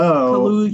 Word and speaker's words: Kaluuya: And then, I had Daniel Kaluuya, if I'm saying his name right, from Get Out. Daniel Kaluuya. – Oh, Kaluuya.